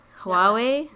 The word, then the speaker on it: Huawei: Like, you know, the, I don't know how to say it, the Huawei, the Hawaii. – Huawei. – Yeah, Huawei.